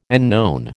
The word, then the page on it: unknown: American English